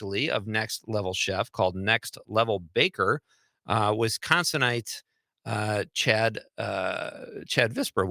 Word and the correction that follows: Visker: will be here. Am I saying that right? I don't know my notes. – Visker. – Yes. Chad Visker.